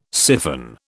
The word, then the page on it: syphon: British English